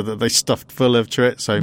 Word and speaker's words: chorizo: I believe it's Thoriso.